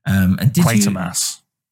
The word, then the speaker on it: Quatermass: Quatermass experiment. – Quatermass.